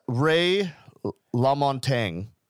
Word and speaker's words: Ray LaMontagne: Ray LaMontagne.